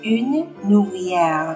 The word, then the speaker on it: ouvrière: une ouvrière，这样连诵，un ouvrier，une ouvrière，好吗？